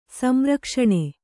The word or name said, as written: ಸಂರಕ್ಷಣೆ